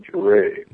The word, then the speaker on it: Johrei: It's spelled J-O-R-E-I or J-O-H-R-E-I, and it's pronounced Joe, as in the name Joe, and Ray, just as in the name for King, Jirei.